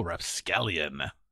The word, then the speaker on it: rapscallion: Oh, rapscallion. You got to say it right.